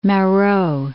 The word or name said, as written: Marot